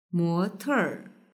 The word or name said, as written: mote